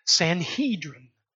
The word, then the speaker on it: Sanhedrin: These three groups of people comprised the Sanhedrin. Sometimes it's pronounced Sanhedrin.